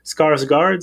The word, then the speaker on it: Skarsgård: Scarsgard, c'est bien ça? – Ouais.